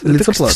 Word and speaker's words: лицоплат: Лицоплат. Это что-то вроде листопада. – Да. Ну вот именно лицоплат надо такое ударение делать.